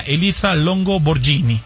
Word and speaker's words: Elisa Longo Borghini: Y el tercer lugar, ya de la tabla general, fue para la italiana Elisa Longo Borghini.